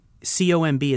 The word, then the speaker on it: COMB: He's saying COMB is a ticker. See, that's where he nerded out. – I was impressed there. I was like, whoa. – I actually call it Comb because it's spelled COMB.